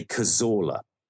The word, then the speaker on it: Cazorla: The Arsenal player, who is from the Basque region of Spain, pronounces his name in that region in Spain as Cazorla, which is obviously spelled with a Z, as I'd say, or a Z, as you'd say. In the English-speaking world, we say Cazorla.